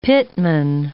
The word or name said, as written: pitman